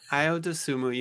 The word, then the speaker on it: Ayo Dosumu: which allowed Ayo Dosumu. Man, I hope I'm pronouncing that right. I'm probably not. – Ayo Dosumu, you got it.